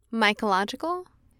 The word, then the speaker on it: mycological: It's mycological.